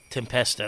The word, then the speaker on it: Tempesta: John Tempesta, is that how you say his name? – Tempesta, that's how I always said it.